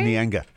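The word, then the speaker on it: Neangar: Well, speaking of lakes, I also really like the one in Eagle Hawk, which is Lake, and I don't know if I pronounce this correctly, but it's No. Nianga.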